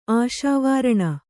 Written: ಆಶಾವಾರಣ

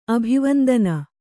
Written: ಅಭಿವಂದನ

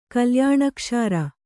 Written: ಕಲ್ಯಾಣಕ್ಷಾರ

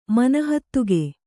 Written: ಮನಹತ್ತುಗೆ

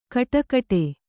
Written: ಗುಡಿಗುಡಿ

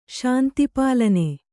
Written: ಶಾಂತಿಪಾಲನೆ

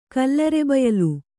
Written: ಕಲ್ಲರೆಬಯಲು

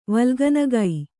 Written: ವಲ್ಗನಗೈ

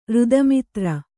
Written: ರುದಮಿತ್ರ

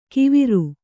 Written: ಕಿವಿರು

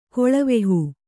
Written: ಕೊಳವೆಹೂ

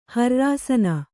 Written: ಹ್ರ್ರಾಸನ

